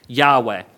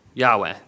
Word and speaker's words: Yahweh: The all capital version of Lord is referring to YHWH, or as we would commonly add the vowels back in and pronounce Yahweh. Yahweh.